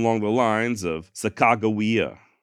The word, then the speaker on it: Sacagawea: And the Limhi Shoshone do maintain that her name is indeed pronounced Sacagawea, just like I'm saying.